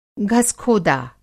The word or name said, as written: घसखोदा